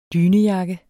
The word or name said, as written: dynejakke